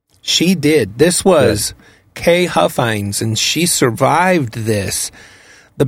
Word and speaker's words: Huffines: Huffines, H-U-F-F-I-N-E-S, age 53.